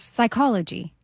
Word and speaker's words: psychology: Psychology. – Correct. The P is silent in psychology.